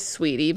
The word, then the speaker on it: Saweetie: Okay, I'm gonna say Saweetie. – Okay, it is Saweetie, but okay.